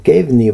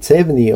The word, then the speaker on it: CEVNI: Noh, õppekava selles mõttes, et Reinil ja ka kohati Toonaul on omad kohalikud reeglid, mida tuleb tunda lisaks siis Kevni või Cevni olen, kes kuidas hääldab, see on siis Euroopa siseveeteede koodeks.